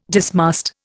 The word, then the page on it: dismast: Indian